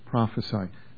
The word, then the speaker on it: prophesy: By the way, if you hadn't noticed this in Scripture, when prophesy is spelled with an S, that's how it's pronounced, prophesy. When it's spelled with a C, it's pronounced prophecy. Prophecy is a noun, prophesy is a verb, with the S as the verb.